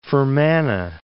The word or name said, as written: Fermanagh